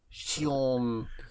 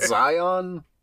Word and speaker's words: Shion: It's not Zion. It's not Zion. She. She. – Because apparently X makes she sound.